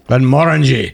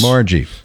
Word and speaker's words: Glenmorangie: Glenmorangie. – Yeah. Yes. – Morangie. – Yeah. – Rhymes with orangey.